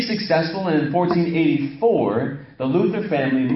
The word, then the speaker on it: Luther: You're going to hear me say Luther and Luther. Because the German way of pronouncing Luther is Luther. Okay, so if you hear me say Luther, I'm not making a mistake. I'm saying it in the way that the German tongue or the German people pronounce Luther.